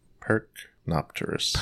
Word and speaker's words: Percnopterus: Neophron Percnopterus. – Percnopterus? – Percnopterus. – Percnopterus. – Yes. – I was adding extra syllables.